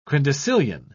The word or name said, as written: quindecillion